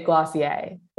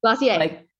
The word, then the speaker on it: Glossier: Oh, you say Glossier. – Glossier. – Like yay at the end. – Yay. We love, okay, I can remember that, Glossier. So you were working with community with Glossier and now with Geneva.